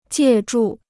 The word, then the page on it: 借助: jiè zhù